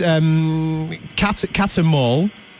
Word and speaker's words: Catermole: It's Catter Mall.